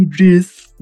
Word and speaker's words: Idris Elba: And then it became Idris Elba. Am I saying that right? – Mm-hmm. – Idris.